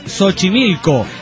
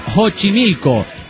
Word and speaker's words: Xochimilco: Xochimilco, dice, se pronuncia Xochimilco y no Xochimilco. cabrón, está, son dos lugares distintos.